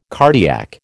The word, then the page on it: cardiac: American English